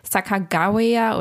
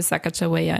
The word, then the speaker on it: Sacagawea: Ich habe sehr viel recherchiert, man kann es auch Sakagawea oder ja, aussprechen, aber ich habe mich jetzt einfach für Sacagawea entschieden.